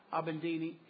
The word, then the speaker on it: Abedini: Abedini.